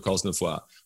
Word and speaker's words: Cosnefroid: You do pronounce the S. I would have had a dozen messages on social media throughout this year's tour. Learn how to pronounce the riders' names correctly. You don't pronounce the S in French for Cosnefroid. Well, according to Benoit, you actually do.